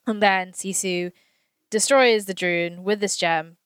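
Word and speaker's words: Druun: And then the dreaded monsters come, the Droon. Is that how you say it? – Droon. – Yeah.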